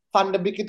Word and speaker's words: Van de Beek: uh, ini pengacara Ten Hag oh pengacara Ten Hag gitu kan yang bilang kalau pelafalan Van de Beek itu bukan uh, apa namanya FDB tapi VDB ya